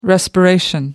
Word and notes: respiration: American English

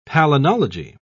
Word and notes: palynology: British English

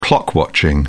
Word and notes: clock watching: British English

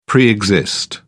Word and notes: pre exist: British English

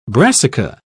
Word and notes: brassica: British English

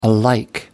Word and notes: alike: American English

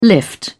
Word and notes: lift: British English

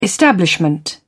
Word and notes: establishment: British English

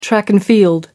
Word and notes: track-and-field: American English

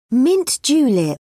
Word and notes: mint julep: British English

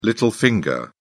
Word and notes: little finger: British English

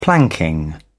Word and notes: planking: British English

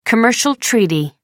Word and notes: commercial treaty: American English

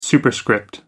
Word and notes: superscript: American English

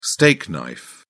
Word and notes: steak knife: British English

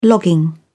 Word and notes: logging: British English

